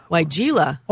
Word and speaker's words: Weigela: Can I trim Weigela? – Weigela.